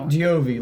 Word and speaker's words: Giovi: I'm a dipshit Irish guy, Giovi, like Bon Jovi.